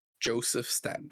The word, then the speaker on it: Joseph Staten: Joseph Staten.